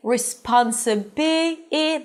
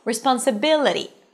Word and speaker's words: responsibility: You see, it's not responsible, it's responsibility. This is the primary stress, responsibility.